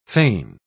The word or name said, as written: fane